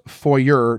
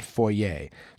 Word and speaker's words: foyer: Another fake French word, foyer, which is pronounced foyer, not foyer.